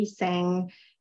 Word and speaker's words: Tseng: Tseng. I'll spell that. So M-A-R-L-A-J, and then I'll spell my last name T-S-E-N-G.